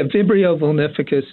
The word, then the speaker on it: Vibrio vulnificus: Yeah, Vibrio vulnificus is the name of the bacteria that's been getting so much press recently.